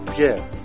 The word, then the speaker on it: Pierre: M-A-R-T-I-N-E. – And yourself, sir? – Pierre. – Pierre, can you spell that for me? – P-I-E-R-R-E.